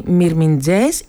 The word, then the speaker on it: Μυρμιντζές: Όχι, Μυρμιντζές ή Μυρμιντζέση, γιατί το «ε» δεν προφέρεται σε ένα πολύ κλειστό και αχνό «ε», περίπου σαν το αγγλικό «α», στη λέξη «about», αλλά στο τέλος της λέξης σχεδόν δεν ακούγεται. Έτσι το «μύρε» ακούγεται σαν «μύρ».